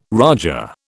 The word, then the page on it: raja: British English